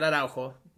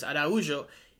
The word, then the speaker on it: Araujo: You also didn't mention that Luis Araujo, not Araujo, you know, it's tempting to say Araujo because that's how it's spelled, but he's Brazilian, so it's Araujo.